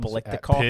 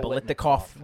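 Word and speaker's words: Biletnikoff: He was a year removed from, what, like 1,900 yards and 18 touchdowns Blitnikoff, is that how you pronounce it? – Yeah. – Yeah. – Let's go with that. – Blitnikoff winner.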